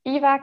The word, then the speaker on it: Eva: So I'm Eva Katernberg on LinkedIn.